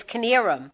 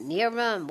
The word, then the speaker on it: Knierim: It's spelled K-N-I-E-R-I-M. So when you look at it, you're like, how do you pronounce that? Is it Nearum? What is that? But it's Kinnearum.